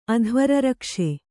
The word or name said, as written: ಅಧ್ವರರಕ್ಷೆ